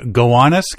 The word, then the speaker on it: Gowanus: I sent you the name of this canal and say, how would you pronounce this? And we're just going to pronounce it properly. – We think. – The Gowanus Canal. – Yeah. – But if you have an 8-year-old mind and go look how it's spelled, it is not Gowanus.